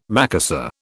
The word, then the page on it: Macassar: British English